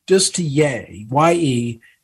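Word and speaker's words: Ye: Well, he pronounces it Yay. – It's Yay. Yeah, Yay.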